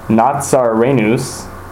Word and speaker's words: Nazarenus: So it's Iesus, and then Nazarenus is of Nazareth, and then Rex is King, and then Iudaiurum, and that's of the Jews.